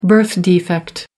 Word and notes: birth defect: American English

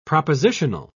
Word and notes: propositional: British English

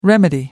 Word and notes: remedy: American English

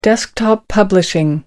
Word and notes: desktop publishing: American English

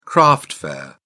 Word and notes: craft fair: British English

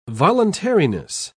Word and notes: voluntariness: British English

